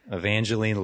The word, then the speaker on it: Evangeline: I can never pronounce her name. Evangeline? Is that what it is? – Yes. Evangeline Lilly.